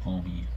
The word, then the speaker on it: Homme: but is it Josh Homme or Josh Home? – It's homie. – Damn. – It took me a lot of time, and I always like, there's no way it's homie. That guy's just mispronouncing it, but it's homie.